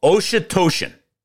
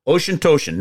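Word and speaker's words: Auchentoshan: Okay, it's called Oshitoshin, all right? And I'm going to spell it for you because if you try to do it phonetically, you're going to mess it up. A-U-C-H-E-N-T-O-S-H-A-N. A-U-C-H-E-N-T-O-S-H-A-N. Oshitoshin.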